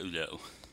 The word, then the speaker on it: Hulot: Monsieur Hulot. – I was going to ask you to pronounce it because I would pronounce it Erlot. Because the French just love not pronouncing all their letters.